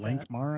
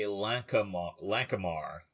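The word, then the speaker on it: Lankhmar: We'll go with Lankmar, that. I guess. – As a kid, I always used to say Lankamar, but I don't think it has that extra syllable in it.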